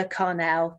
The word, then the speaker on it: Carnell: My name is Paula Carnell, two L's, spelt with a C, C-A-R-N-E-L-L.